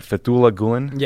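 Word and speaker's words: Fethullah Gülen: So, Ben, who is, am I saying his name right, Fethullah Gulen? – Yes, yeah.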